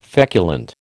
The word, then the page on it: feculent: American English